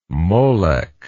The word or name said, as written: molech